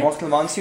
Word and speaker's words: Mortelmans: I actually want to say it in Afrikaans accent, Mortelmans, right? – Mortelmans, you can also say it like that, yeah. – Because if you try to say it in an English accent, it's like Mortelmans.